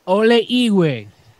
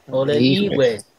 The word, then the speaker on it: Ole Igwe: Well, over here I have the Olehig, I think it is pronounced. – Olehigwe. – Olehigwe. No, well, it means in English it would be old hag.